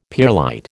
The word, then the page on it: pearlite: American English